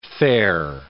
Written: fare

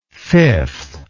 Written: fifthly

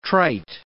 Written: trite

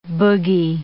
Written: bogey

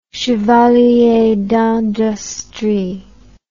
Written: chevalier d'industrie

